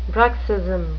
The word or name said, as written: bruxism